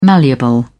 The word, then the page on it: malleable: British English